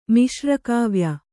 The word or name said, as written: ಮಿಶ್ರಕಾವ್ಯ